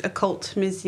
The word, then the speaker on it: occult: That's not how you say it. It's not your cult. – Occult. – Occult. – I can't say it. – Occult. – Occult. Because it's a cult.